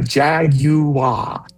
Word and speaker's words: Jaguar: You've got to make sure you've got enough syllables in there. Jaguar.